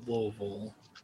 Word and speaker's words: Louisville: Made my trip back to Louisville. – Thank you for saying it correctly.